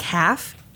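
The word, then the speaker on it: Halff: It's spelled like half. H-A-L-F. – Oh. Okay. – But it's pronounced Half. It's a German name.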